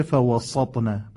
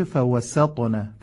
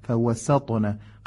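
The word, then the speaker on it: فَوَسَطْنَ: فوسطن به جمعا، در خوندن فوسطن دقت کنید، چون طایی حرف قویه در زبان عربی، حروف مجاور خودش رو به سمت خودش میکشه، نشه فوسطن، باید بشه فوسطن، فوسطن، قشنگ حرف سین تلفظ بشه.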